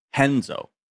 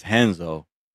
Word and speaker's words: Renzo: Henzo. – It's, I mean, yeah, because in Brazil, the R's are H's, you know? Oh. So it's spelled like Renzo, but it's Henzo.